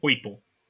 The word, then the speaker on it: Poipole: Poipole, sorry, said the name wrong. Poipole.